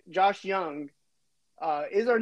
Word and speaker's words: Jung: You know, Josh Young, but it's spelled Jung.